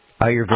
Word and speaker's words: Ayurvedic: Ayurvedic.